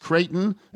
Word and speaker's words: Creighton: So your final four, Phil's mom's final four, is Creighton, how it's actually pronounced, Creighton and Colorado, and Texas Tech and Clemson.